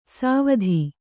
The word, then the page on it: ಸಾವಧಿ: sāvadhi